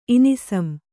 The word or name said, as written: ಇನಿಸುಂ